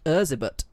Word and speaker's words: Erzsébet: Her name is actually pronounced Erzsébet. That's the Hungarian way of saying Elizabeth.